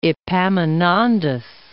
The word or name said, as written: Epaminondas